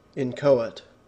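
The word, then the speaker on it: inchoate: And in my head, it's well, I pronounce it phonetically, incohate. I've got this wrong, haven't I? – I don't know. I've always said incohate when I've said it out loud. How, let's see what pronunciation book says. – Incoate. Incoate. – No H in there.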